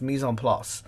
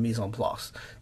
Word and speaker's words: mise en place: It's a French term. I don't speak French, but I think it's pronounced mise en place, which means everything in its place, and do the preparation.